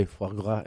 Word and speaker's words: foie gras: How do you say it? – Foie gras. I can't sometimes gras. – I can't do the full roll.